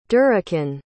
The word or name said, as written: durriken